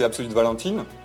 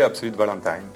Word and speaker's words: Absolute Valentine: Mais alors, euh, dis-moi, Absolute Valentine euh, ou Absolute Valentine, déjà, c'est la bonne prononciation, c'est quoi bah, En français, on va dire c'est Absolute Valentine. D'accord. Voilà. Et en anglais, Absolute Valentine.